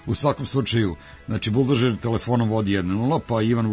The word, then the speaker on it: Buldožer: Buldožer s akcentom na O, buldožer, to je sad sam se ja, počeo sam polako da se gubim sa svim ovim glasovima, priznajem.